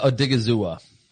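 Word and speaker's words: Odighizuwa: Osa Adegawuzo, Awuzi. I messed that up. Give me the name. How do you pronounce his name out of UCLA? – Osa Adegazua. – Osa Adegazua. Okay, that's easy enough.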